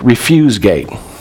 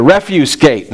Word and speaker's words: refuse: Now, I know that looks like refuse gate. Like, we're talking about the refuse gate.